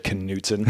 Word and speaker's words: Knudsen: Easy said, Knudsen. Who can Knudsen. Fuck that up? – Nobody pronounces it right ever. – I just what did. – Congrats. – And so did he. – Yeah. So that's two.